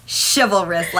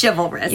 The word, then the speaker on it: chivalrous: If you guys don't know how it's spelled with a C-H. So it's really like chivalrous, but it's pronounced chivalrous, like S-H, chivalrous.